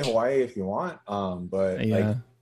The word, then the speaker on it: Hawaii: And then in Hawaiian language, the W can be pronounced as a V. So you would normally say like Hawaii if you were speaking proper, like, you know, native Hawaiian language kind of thing.